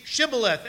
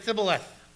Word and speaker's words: Shibboleth: And so they would say Shibboleth, and the Ephraimites would say Thibboleth. They couldn't pronounce it.